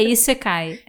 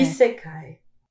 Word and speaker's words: Isekai: Eu sei que teve uma, uma, questão dessa de qual era a pronúncia e agora eu não me lembro. É Isekai. É. Isekai. Boa.